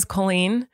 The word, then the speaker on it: Colleen: Colleen, two syllables.